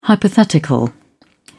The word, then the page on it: hypothetical: British English